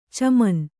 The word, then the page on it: ಛಮ್ಮನೆ: Kannada